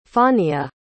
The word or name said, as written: fawnia